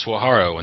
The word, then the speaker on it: Saguaro: Now just what, playing Saguaro, our everybody's personal favorite. – Which is pronounced Swaharo in the desert, but it's okay.